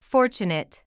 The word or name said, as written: fortunate